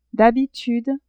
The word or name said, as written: d'habitude